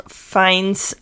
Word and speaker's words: Fiennes: Ralph Fiennes, I never know how to say his last name.